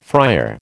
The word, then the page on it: fryer: American English